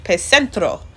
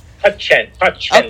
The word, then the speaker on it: Pacentro: He now splits his time between his native New York and his ancestral Italian village, Pacentro. Did I say that right? – Pacentro.